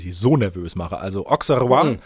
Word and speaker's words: Auxerrois: Wie spricht man sie auf? – Auxerrois. – Sehr oh. Du musst das Mikrofon verschlucken und Auxerrois.